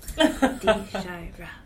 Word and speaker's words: Dshyra: Okay, well, my name is Dshyra, last name Hobbs, but most people call me Shy because they can't pronounce Dshyra, even though it's three syllables. Dshyra.